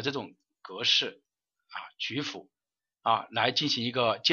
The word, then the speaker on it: 曲阜: 第二个就是鲁国国都曲部啊，应该说叫曲阜是吧？叫曲什么？呃，同学呃打个发音过来啊。叫曲什么？曲阜还是曲阜啊？哎，啊，曲阜是吧？好，呃，鲁国的国都曲阜啊，它是按周制建制的啊。